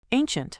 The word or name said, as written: ancient